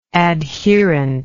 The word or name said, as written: adherently